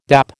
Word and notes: dap: American English

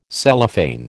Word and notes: Cellophane: American English

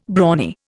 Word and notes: brawny: Indian

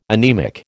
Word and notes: anemic: American English

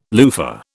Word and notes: loofah: British English

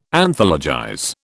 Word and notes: Anthologize: British English

